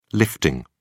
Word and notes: lifting: British English

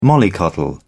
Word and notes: mollycoddle: British English

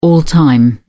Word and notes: all-time: British English

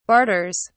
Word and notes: barters: British English

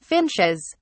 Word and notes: finches: British English

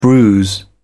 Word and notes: bruise: British English

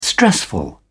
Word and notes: stressful: British English

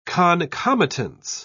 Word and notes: concomitance: British English

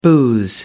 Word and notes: booze: American English

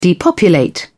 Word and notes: depopulate: British English

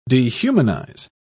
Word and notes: dehumanise: British English

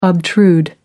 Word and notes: obtrude: American English

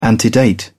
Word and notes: antedate: British English

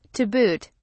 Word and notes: to boot: British English

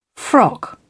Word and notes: frock: British English

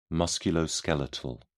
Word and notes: musculoskeletal: British English

